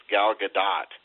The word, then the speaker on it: Gadot: Yeah, and I think it actually, even though I had 5 years of French, I think they actually pronounce the hard T. I think it's Gal Gadot.